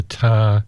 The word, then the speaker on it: Thar: So late in the afternoon, our coach headed west towards a small village deep in the Thar Desert. Now, it's spelled T-H-A-R.